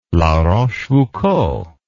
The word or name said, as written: la rochefoucauld